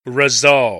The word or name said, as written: resolve